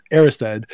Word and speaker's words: Erised: which is how you pronounce that song that it looks like it's pronounced Arised. It's actually pronounced Aristide.